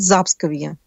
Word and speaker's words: Запсковье: Пскова и окажетесь за Псковой от Кремля, то это будет Запсковье. И ударение тоже на первый слог.